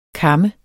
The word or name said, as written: kam